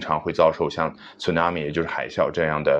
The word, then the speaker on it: tsunami: 然后呢他们的发音叫 tsunami。 tsunami 啊，euao 啊，日语当中有一个比较奇怪的这个发音叫 u 啊，英文当中呢，它只能用相似的那个 u 啊来代替。